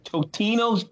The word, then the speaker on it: Totino's: Homemade bread versus Tostino rolls. Is that, am I saying that right? – Totino's pizza rolls.